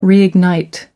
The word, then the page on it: reignite: American English